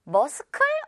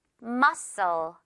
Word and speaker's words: muscle: 머스클 어떻게 읽어야 되지? S 발음만 하면 된다고 했죠? Muscle, muscle 이렇게 읽으면 됩니다.